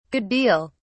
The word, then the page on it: good deal: British English